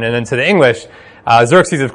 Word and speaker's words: Xerxes: The letter that's pronounced Kha in Greek is X, which Americans pronounce however they feel like it. So they pronounce it Xerxes.